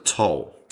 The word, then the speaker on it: Tolle: Is it Eckhart Tolle or is it Toll? – Toll.